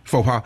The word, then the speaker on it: faux pas: Now, I'd like to know, what is a major, is it faux pas? Is that how you say it? – Yeah. – Faux pas.